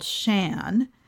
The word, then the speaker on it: Sian: It's called Shan, and it is Scottish spelling. So if you look it up on Ravelry, it is spelled S-I-A-N.